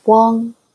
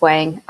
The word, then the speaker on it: Wang: In Chinese, it's Wang. So, but I just use Wang. I have always just gone with that. I feel like it sounds like more cohesive when I say Janna Wang.